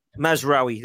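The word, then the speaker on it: Mazraoui: Masraoui.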